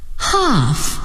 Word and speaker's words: half: Half. Half an hour. Calm. Keep calm. 今天时间很短，不过我们学了字母组合 AL 的发音，它们可以发 O 和 A。